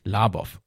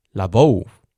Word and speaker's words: Labov: Nämlich, ich habe die ganze Zeit, so wie ich es auch gelernt habe, die eingedeutschte Version des Namens genannt, nämlich Labov. Eigentlich heißt er, beziehungsweise wird sein Name ausgesprochen als Labov, also eine andere Betonung und eine andere Art der Aussprache.